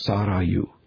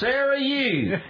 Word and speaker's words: Sarayu: Phonetically, it would be like S-A-W, saw. And then the middle initial is R-A-H, like raw, but it's rolled R, and then U, Y-O-U. So Sarayu.